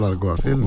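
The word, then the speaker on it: Alguacil: Αλλά στην πρώτη στραβή, όπως έγινε και με τον Αλκουαθίλ, έτσι λένε, σωστά δεν προφέρω. – Ο Ιμανόλ Αλκουαθίλ.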